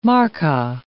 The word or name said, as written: markkaa